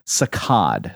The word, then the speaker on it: saccade: I kept saying saccade in my last question of the last episode. The French term for jerk that relates to the, on average, 80 eye movements that your eye makes, the jerky eye movements. It should be pronounced saccade. Saccade is the correct pronunciation there.